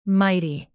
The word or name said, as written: mighty